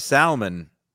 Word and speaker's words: salmon: Salmon. It's spelled salmon. There's an L there, folks. Fucking wake the fuck up.